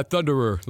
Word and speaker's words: Thunderer: Yeah, Thunderer. Like, think of like a conqueror, but like Thunderer.